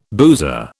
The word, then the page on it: boozer: British English